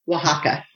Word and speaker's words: Oaxaca: How do they get Oaxaca out of that? – They pronounce the X differently.